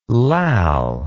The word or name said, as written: lall